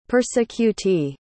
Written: persecutee

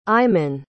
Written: iman

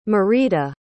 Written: merida

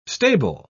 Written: stable

